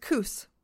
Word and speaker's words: Coos: Yeah, it's pronounced Coos.